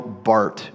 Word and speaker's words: Barth: One of the most famous theologians in the 20th century was named Karl Barth, B A R T H, pronounced Bart.